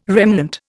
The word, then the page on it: remnant: Indian